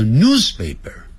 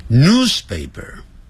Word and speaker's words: newspaper: newspaper 前面加个 N-E-W-S news 不要念成 news news 是英式英语 newspaper newspaper 不要念成 newspaper 不是这样念 newspaper 啊，